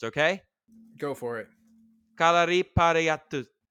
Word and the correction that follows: Kalaripayattu: Okay, it's K A L A R I P A Y A T T U. Okay, I'm trying. It's a long word. Kalari Kalaripayattu.